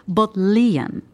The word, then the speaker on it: Bodleian: Maar ehm, het is eigenlijk Bodleian. Let even op de klemtoon.